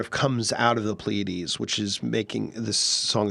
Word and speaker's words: Pleiades: This song is called Pleiades. Am I pronouncing that correctly? – Pleiades. – Pleiades, yeah.